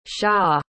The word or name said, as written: hsia